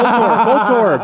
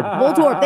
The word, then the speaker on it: Voltorb: So he's basically, like Voltorb, I can't even say it right now, the Pokemon. – Yeah. – Anyway, oh. Voltorb. – Voltorb, thank you.